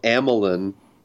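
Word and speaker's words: Amilyn: And so Holdo, Amelin. Is that how they say it? – Yeah. – In the audiobook? – Yes.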